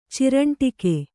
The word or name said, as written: ಚಿರಂಟಿಕೆ